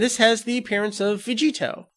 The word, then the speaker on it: Vegito: The name Vegito is a combination of Kakaroto and Vegeta. Now, you take the Vegi and the To, it's a hard To sound at the end there.